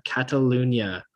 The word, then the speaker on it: Catalunya: All right, next up, we've got a big race this Sunday, another big race, it's the Spanish Grand Prix, the circuit, the Barcelona, Catalunya, that's how you say it,